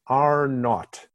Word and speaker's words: R naught: In um, understanding how infectious diseases spread in the population, there is a concept called R naught. It's a big R and a little sub zero, and it's pronounced in the British way R naught.